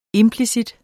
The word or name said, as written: implicit